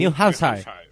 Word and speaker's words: New Hampshire: ¿Cómo se pronuncia? El estado de New Hampshire o algo así. – New Hampshire.